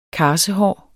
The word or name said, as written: karsehår